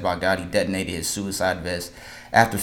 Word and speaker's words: Baghdadi: Baghdadi.